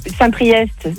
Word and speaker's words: Saint-Priest: T'as Saint-Priest, c'est EST, tu le prononces. Chaponneau, c'est ST, tu le prononces pas.